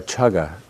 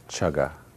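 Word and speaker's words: cāga: Yeah, chaga. C-A-G-G-A. – And the C is spelled like a C-H. – Yeah, chaga.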